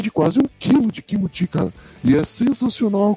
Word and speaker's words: kimchi: Kimchi? É, é coreano? – É, é, é, que na pronúncia pro japonês fica kimuti E no Sim. coreano é kimchi tá ligado?